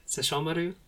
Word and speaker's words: Sesshomaru: Did I say that right? Sesshomaru? Yeah. Yes.